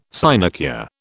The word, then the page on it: sinecure: British English